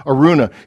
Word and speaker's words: Araunah: You pronounced it very well, brother. In fact, the English pronunciation is no different from the Hebrew pronunciation, Araunah.